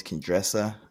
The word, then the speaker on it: Qendresa: Her name is Qendresa. I think that's how you pronounce it. Q-E-N-D-R-E-S-A. Qendresa.